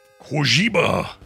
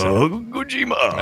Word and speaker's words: Kojima: Kojima. Is how you say Kojima. – That's right.